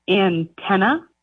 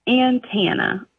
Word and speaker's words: antenna: So the way I say it, antenna, A-N-T-E-N, like the number 10, U-H. Antenna. – Mm-hmm. – And he says it antenna, like an apple.